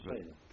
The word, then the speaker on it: Cesena: Cesena.